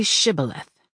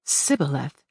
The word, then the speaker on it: Shibboleth: If he replied, "No," they said, "All right, say Shibboleth." If he said Sibboleth, because he could not pronounce the word correctly, they seized and killed him at the fords of the Jordan.